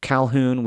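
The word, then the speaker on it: Colquhoun: but the Culquhouns, which I know is not, that Q in the middle of it is just out of place. And I've said it's Calhoun.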